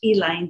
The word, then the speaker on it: Elaine: That and the Tulsa race riots, the Elaine, we call it in Arkansas, has to be pronounced Elaine.